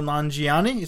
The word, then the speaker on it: Nanjiani: And it's starring Kumail Nanjiani.